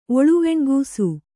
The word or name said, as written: ಒಳುವೆಣ್ಗೂಸು